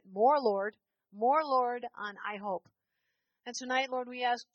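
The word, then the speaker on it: IHOP: God, we bless the I Hope. That's how you say it, right? Yeah, we bless I Hope.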